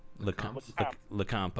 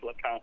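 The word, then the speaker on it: Lecomte: Lecompte? What is it? – Lecompte. – Okay. – You pronounce it like you know it, yeah, like you're French. Lecompte.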